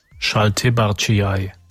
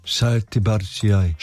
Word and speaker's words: Šaltibarčiai: so wird sie ausgesprochen. Wir haben ja einen Knopf. – Šaltibarčiai. – Šaltibarčiai.